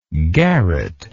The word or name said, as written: garreted